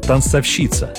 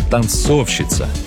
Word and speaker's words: танцовщица: Многие ставят ударение на третий слог – танцовщица. Но, увы, орфоэпические словари допускают один единственный правильный вариант. С ударением на втором слоге – танцовщица. Справочник «Русское словесное ударение» особо помечает – не танцовщица. Так что придется принять – говорить «танцовщица» – это ошибка.